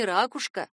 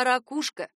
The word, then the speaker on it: ракушка: Хотя в то время, когда снимались эти фильмы, единственным верным вариантом была не ракушка, а ракушка.